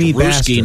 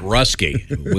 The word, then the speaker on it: Ruski: And it is pronounced Ruski, not Rusky.